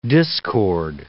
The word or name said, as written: discord